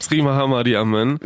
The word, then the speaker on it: Sri Maha Mariamman: Oder wie man richtig sagt? – Sri Maha Mariamman. – Genau, so wird es richtig ausgesprochen, mit bunten Farben, Musik, Gebeten und so weiter.